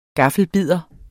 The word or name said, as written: gaffelbidder